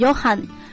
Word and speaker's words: Johann: 哎，开玩笑，其实这个词它之所以翻译成约翰啊，是因为它的原型就念 Johan， J-O-H-A-N-N， Johan。那么它简化为 John， J-O-H-N 之后呢，中文翻译却保留了他那个词源的发音，所以翻译为约翰。